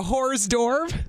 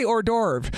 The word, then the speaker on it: hors d'oeuvre: I say hors d'oeuvre. – Hors d'oeuvre? – What did you call it? A horse d'oeuvres? – Horse d'oeuvres. – No, you don't pronounce the H.